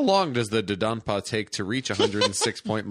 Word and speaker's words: Dodonpa: Where is the Dondonpa roller coaster located? – Man, they haven't even got the name right. It's the Dondonpa. The Dodonpa. Dodonpa. – Yeah, that's probably just my pronunciation. It's Dodonpa.